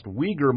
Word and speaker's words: Uyghur: pronounced Uyghur Muslims, regard as East Turkestan or Uyghurstan.